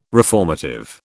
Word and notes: reformative: British English

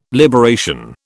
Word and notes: liberation: British English

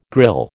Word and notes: grille: American English

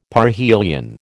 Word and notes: parhelion: American English

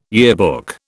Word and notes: yearbook: British English

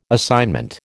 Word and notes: assignment: American English

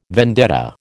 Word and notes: vendetta: American English

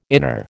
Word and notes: inner: American English

